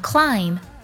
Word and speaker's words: climb: 第三组呢，就是 m b 结尾。 b 不发音，像 climb 攀爬，还有 thumb 拇指，它们结尾的 b 呢都不发音。